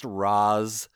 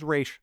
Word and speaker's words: Ra's: I am Ra's al Ghul, he says, and a bunch of people, including my esteemed co-host, violently shake their heads and say, actually, it's pronounced Ra's. Yeah, okay, but he says Ra's, so I say Ra's.